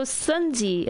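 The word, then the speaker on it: Xunzi: So Sunzi again.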